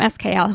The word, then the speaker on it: Eskayel: Uh, Shanan from Eskayel, who I've had on the radio show before, Eskayel is her company, and it's spelled E S K A Y E L.